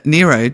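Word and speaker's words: Nyro: Is it Nero doing or Nero? Nero, yeah, actually. N-Y. – Nero. No, Laura Nero, yeah. It's spelt N-Y, so it fools me, but, yeah.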